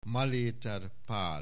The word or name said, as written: Maléter Pál